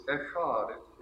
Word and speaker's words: echad: The Hebrew word is echad. It's spelled in English. It would be E-C-H-A-D.